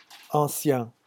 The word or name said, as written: ancien